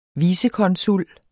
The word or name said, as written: vicekonsul